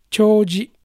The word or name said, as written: Choji